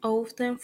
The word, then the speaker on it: often: Frequency adverb, hundred, always, nine, usually, 18, normally, generally, 7, often, frequently, 15, sometimes, 13, occasionally, 10, seldom, 5, rarely, ever, rarely, 0, never. Some people pronounce the T in often, but many others do not.